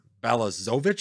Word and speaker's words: Balazovic: Guys like Rice, Lewis, Austin Martin, uh, Jordan Balazovic, I believe is how you pronounce that wonky last yep.